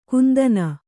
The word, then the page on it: ಗಂಧನ: gandhana